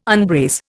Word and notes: unbrace: Indian

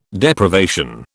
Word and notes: Deprivation: British English

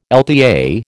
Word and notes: LTA: American English

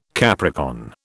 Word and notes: Capricorn: British English

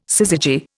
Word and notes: syzygy: Indian

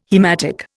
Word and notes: haematic: Indian

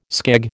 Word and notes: skeg: American English

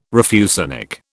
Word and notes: refusenik: British English

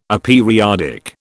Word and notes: aperiodic: British English

